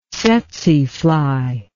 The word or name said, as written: tsetsefly